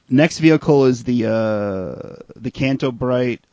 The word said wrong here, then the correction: Canto Bight: police. I'm sorry, I'm saying this wrong. It's the Canto Bight police speeder.